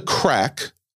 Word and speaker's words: craic: He says, what's the crack? I Googled this. It's spelled C-R-A-I-C. This is like a Gaelic term.